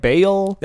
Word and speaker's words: Baal: We saw Ball the Storm God. – Ball the Storm God. – That's how they pronounce it. – Yeah. It's usually pronounced like Bale.